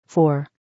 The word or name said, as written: 4